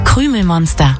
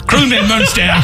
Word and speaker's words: Krümelmonster: Listen to how it's pronounced in Germany. – Krümelmonster. – Krümelmonster.